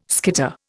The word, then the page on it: skitter: Indian